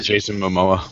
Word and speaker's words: Momoa: And when I heard that they had cast, is it Momoa? How do you pronounce it? – Yeah, Jason Momoa.